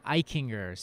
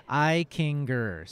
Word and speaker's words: Eichinger: Eichinger is not bad. It's kind of some of my family actually does pronounce it that way, but we'll say Eichinger. So Eichinger.